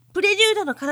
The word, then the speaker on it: 前奏曲: 別名、プレリュードの要でございます。そうです。前奏曲と書いて、プレリュードと読む、プレリュードの要です。